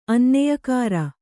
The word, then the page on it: ಅನ್ನೆಯಕಾರ: Kannada